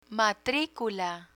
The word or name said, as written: matricula